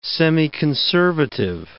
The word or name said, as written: semiconservative